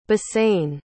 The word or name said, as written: bassein